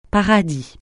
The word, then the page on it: paradis: francais